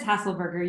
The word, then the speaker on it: Haselberger: Yep, it's Haselberger.